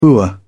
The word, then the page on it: boor: British English